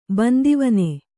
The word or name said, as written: ಬಂದಿವನೆ